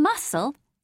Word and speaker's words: muscle: In muscle, SC is S. In muscular, it's SK, yes.